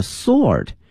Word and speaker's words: sword: 一个呢是僧人用的这个铲子，用的是 spade, S-P-A-D-E. 另外看一下这个剑啊，它的发音是 sword, sword. 虽然它的拼写呢是 S-W-O-R-D, 但是同学们要记住了，这个 W 是不发音的 ,sword,sword, 以及呢三节棍 ,three section, 啊三个部分的这个 staff,three section staff,S-T-A-F-F, 以及呢 chain whip,chain 表示链子的鞭，链子的鞭呢翻译成中文叫九节鞭啊。